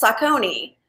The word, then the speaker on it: Saucony: It's called, it's S-A-U-C-O-N-Y. – Yeah, I wouldn't know how to pronounce that. – Okay, well, most people think it's Saucony, but it's Saucony.